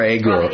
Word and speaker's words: Agur: Agur, A-G-U-R. – Oh, is that Agur or Agur? – Agur.